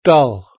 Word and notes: doll: British English

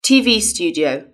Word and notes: tv studio: British English

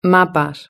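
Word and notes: mapas: Spanish (Spain)

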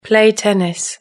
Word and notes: play tennis: British English

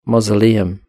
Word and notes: mausoleum: British English

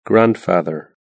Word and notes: grandfather: British English

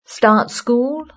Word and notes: start school: British English

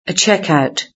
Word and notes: checkout: British English

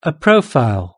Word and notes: a profile: British English

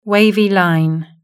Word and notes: wavy line: British English

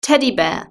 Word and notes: teddy bear: British English

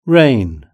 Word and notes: rain: British English

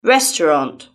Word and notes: restaurant: British English

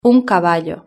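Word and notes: un caballo: European Spanish

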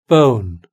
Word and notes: bone: British English

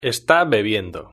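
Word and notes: esta bebiendo: Spanish (Spain)

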